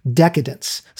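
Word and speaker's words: Decaydance: Not long after their departure for Island Records, Pete Wentz and Patrick Stump launched their own label, Decadence, spelled like Decay Dance, as a subsidiary of Fueled by Ramen.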